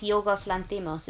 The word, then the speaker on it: Yorgos Lanthimos: Yorgos. I'm just sticking my tongue out and hoping for the best right now. – I think it's Yorgos Lanthimos, isn't it? – Lanthimos.